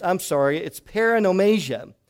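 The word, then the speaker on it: paronomasia: I don't know if you've ever heard the word poromasia. The word is pronounced like this. It's in English class, and it's paranomasia. Paranomasia.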